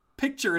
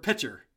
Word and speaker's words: picture: So this is what I learned about myself, and I honestly did not know this, and I do not pronounce the word picture correctly. I say picture as if it were the word pitcher.